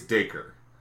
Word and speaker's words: Dacre: I think it's Dacre. – Okay. – I thought you said Digger. – I know it's spelled D-A-C-R-E.